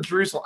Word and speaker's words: Drusel: Is it Drusel or Drusel? I, I screw this up all the time. It just rhymes with muscle.